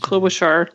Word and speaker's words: Klobuchar: How do you pronounce Klobuchar.